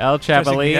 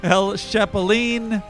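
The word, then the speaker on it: Chapulín: Oh, hard H sound. I see. He's got it. I'm sorry. It's hard CH sound. – El Chapulin. – Accented syllable. El Chapulin.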